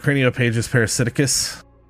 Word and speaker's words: craniopagus parasiticus: Was that craniopagus parasiticus? – Yeah, nailed it.